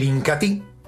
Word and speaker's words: Incatea: Y otro de los productos líderes de la aldea Ramazón, sin lugar a dudas, mis amigos, es el Incatí. Se escribe Incatea, pero se pronuncia Incatí.